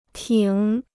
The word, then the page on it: 挺: tǐng